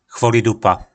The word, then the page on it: chvolidupa: po našimu